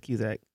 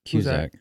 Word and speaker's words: Cusack: How you say his name? His last name. I think it's Cusack. – Cusack. – Yeah.